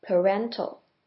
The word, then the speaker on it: parental: 而 parental 前面的 pa 是发成 p 那个 a 是发成 r 的声音，跟 parent 的 pa 念法不一样，这个字是念成 parental。